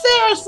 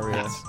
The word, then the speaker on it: Saria: It's no, Saria. – I always say Sarah. – Me. I've definitely... It's spelled S-A-R-I-A, correct? – Yes.